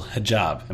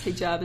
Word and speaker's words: hijab: See, and you have, the people can't see, you have a beautiful hijab. Am I saying it correctly? – Yes, hijab is the Arabic word for a scarf.